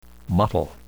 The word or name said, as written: mottle